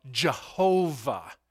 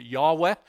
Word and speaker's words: Yahweh: Some of our friends of another religion that is cultic in nature calls themselves witnesses of Jehovah. That is not how the word is pronounced in Hebrew at all. As a matter of fact, there's no J sound in any of Hebrew. It's not there.